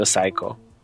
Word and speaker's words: psycho: To mi się kojarzy z American Psycho, S-I-C-K-O. – Jasne. – I to jest mój problem akurat, ale tak, masz rację, czyta się Psycho.